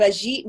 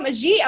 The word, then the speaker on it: Ajit: And today, I have Ajit Nawaka. I hope I'm saying that right. Do I have it right? – You got it right.